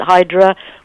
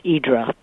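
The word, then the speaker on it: Idra (Ύδρα): It's kind of a joke on Idra because tourists call it Hydra, but the natives, because in Greek letters it's Y-D-R-A, and it's pronounced Idra by everyone who actually lives there or stays there for any length of time.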